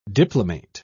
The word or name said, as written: diplomate